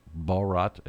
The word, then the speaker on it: Bharat: It spells C-I-L-L-I-A-N. It's not Cillian. He pronounces it Cillian. The word the locals have for India, pronounced Bharat,